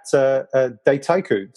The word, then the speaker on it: Dataiku: Florian Douetteau is the CEO and a co-founder at Dataiku.